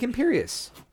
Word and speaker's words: Imperius: Am I saying it wrong? – I don't know. It's a fucking made-up word. – No, it's not. It's like Imperius.